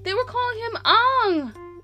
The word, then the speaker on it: Aang: It bothered me the whole movie because Aang, A-A-N-G, Aang, Aang is pronounced Aang.